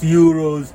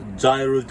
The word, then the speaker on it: gyro: As you guys know, when I was in Ukraine half a year ago, shawarma, Middle Eastern food, Euros, gyros, depending how you want to pronounce it. You know, it's spelled gyro, but it's pronounced Euro, you know.